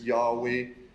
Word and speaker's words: Yahweh: And you know, we know we get those, the word, the tetragrammaton, really, the YHWH that we pronounce as Yahweh. You know, some people pronounce it as Jehovah.